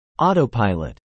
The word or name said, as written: autopilot